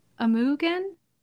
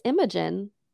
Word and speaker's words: Imogen: And even Charlie's girlfriend, Amy, who's played by, I believe you say her name, Amugen? – No. – How do you say it? – I'm pretty sure it's Imogen. – Imogen? – Yeah.